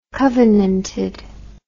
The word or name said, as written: covenanted